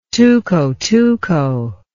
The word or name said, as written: tuco-tuco